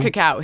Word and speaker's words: cacao: But that's not like cocoa. It's cacao? – No, it's cacao. C-A-C-A-O.